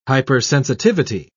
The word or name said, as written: hypersensitivity